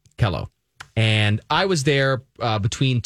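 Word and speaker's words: KELO: A college friend of mine did weather at Kilo in the morning. – It's Kello, honey. You're saying it wrong.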